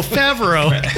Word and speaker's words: Favreau: Favreau. Yeah. – Sickeningly. – Favre? Is that how you say his name? No, Favreau.